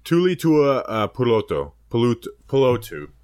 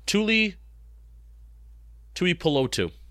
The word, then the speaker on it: Tuipulotu: Tulitua Pulotu, edge out of USC. Did I pronounce that right? – Tui Pulotu. I think it's pronounced exactly how it's kind of spelled out.